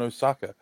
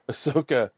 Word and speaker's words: Ahsoka: So the Dave Filoni movie is going to be a culmination of Mandalorian and I'm not going to mispronounce her name again. – Ahsoka. – Thank you. I don't know why I stuck on Osaka. Ahsoka.